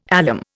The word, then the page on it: alum: Indian